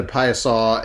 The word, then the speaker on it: Piasa: You know, like I think of Alton, Illinois and the Piasaw. I was trying to remember how to pronounce it. I was going to say Piasa, and then I realized Piasa. – It's not that. The Piasaw, and you've got that in a lot of states around the country.